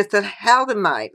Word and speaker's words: thalidomide: And she contributed to the ban, and this really hit my heart, to thalidomide.